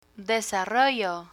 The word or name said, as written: desarrollo